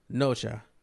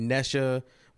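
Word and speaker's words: Nezha: We're going to talk about this movie that is spelled N-E, capital Z-H-A, but is pronounced Nocha. If you get confused reading that and telling people you're going to see Nesha, which sounds like a new R&B artist, or Nisha, which sounds like another new R&B artist, but sounds like Tweet.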